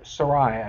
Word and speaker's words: Sarai: Or Sarai, I guess, is the way you pronounce her name at this moment.